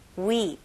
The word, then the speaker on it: weep: 영어로는 모음들이 좀 더 길다고 생각하시면 좋을 것 같아요. 그래서 우리나라 발음으로 그냥 weep 이런 식으로 할 텐데 영어 발음은 좀 길게 weep, weep.